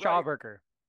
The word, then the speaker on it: Schauberger: But from there, Strasbourg. Did I say it right? – Schauberger. – Schauberger. Shit. I keep adding that T.